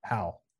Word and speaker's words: Houck: It's not Houck. It's Houck. – John Houck. – Like H, like it's said, it's pronounced like H-A-W. Houck.